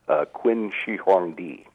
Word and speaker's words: Qin Shi Huangdi: Qin Shi Huangdi.